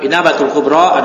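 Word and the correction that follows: Ibanatul Kubra (الإبانة الكبرى): Ya Ibanatul Kubra, ya.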